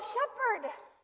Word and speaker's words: Shepherd: This is Calvin Shepherd. – Rhymes with leopard.